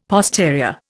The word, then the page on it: posterior: Indian